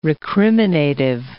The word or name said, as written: recriminative